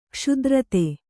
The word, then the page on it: ಕ್ಷುಧಾರ್ತ: kṣudhārta